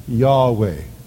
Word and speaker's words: Yahweh: No one knows for sure how to pronounce it. But most likely it is pronounced something like Yahweh.